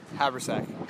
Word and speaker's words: Habersack: Did I say the name right? – Habersack. – Habersack, that's what I said.